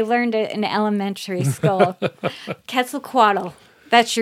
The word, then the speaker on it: Quetzalcoatl: The Aztec pronunciation is Quetzalcoatl, and I think my children are about the only ones that ever learned how to do it that way.